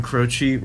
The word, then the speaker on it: Croce: Before that, I Got a Name by Jim Croce, right? – Yes. I pronounced that right.